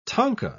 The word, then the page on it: tanka: British English